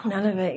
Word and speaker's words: Nunavut: Oh, Nunavut, yes.